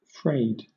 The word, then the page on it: frayed: British English